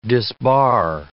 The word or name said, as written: disbar